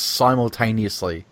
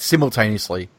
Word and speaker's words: simultaneously: That word is pronounced that way in North America. It's not pronounced that way in Great Britain or Australia at all. We say it simultaneously, like something is simulcast on the radio.